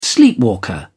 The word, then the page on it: sleepwalker: British English